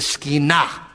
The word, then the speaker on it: Shekinah: So we like to say Shekinah. That's English for, in Hebrew, you always put the syllable on the last syllable. So it should be the Shekinah. That's the right way to say it.